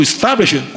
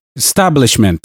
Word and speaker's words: establishment: Eu estou lutando contra um sistema, contra o establishment, contra o establishment, establishment. – Não, você tá falando errado. É, fala, repete comigo. Es, es, tá, tá, blish, blish, ment, ment, establishment.